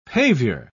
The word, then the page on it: pavior: British English